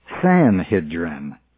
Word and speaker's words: Sanhedrin: Sanhedrin is our translation of that, but more properly, according to the dictionary, it's Sanhedrin. According to Unger's Bible Dictionary, Sanhedrin. So I go with the dictionary rather than with Unger on this, and the proper pronunciation ought to be Sanhedrin.